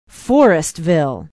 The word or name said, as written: Forestville